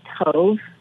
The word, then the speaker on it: tov: Am I saying TAV right? Is that right? – It's actually a long O, TOV.